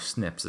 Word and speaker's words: SNPs: And they call these different sequencing of DNA, they're called SNPs. S-N-P, or SNPs is how it's pronounced for short.